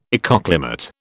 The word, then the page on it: ecoclimate: British English